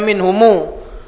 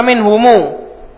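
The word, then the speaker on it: minhumu (مِنْهُمُو): Sayyidullahu kullan minhumu, bacanya minhumu karena darurah. Apa namanya? Arud. Arud syair, timbangan syair. Jadi dibaca minhumu.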